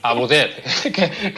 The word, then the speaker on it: Abouzeid: Muy bien, pues eh, Dina Abucet, ¿cómo se dice tu apellido? – Abuzet, en árabe. – Vale, Abuzet, que tiene su dificultad.